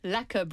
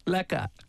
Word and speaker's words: lekker: Doing well, lekker, bro. – Lekker.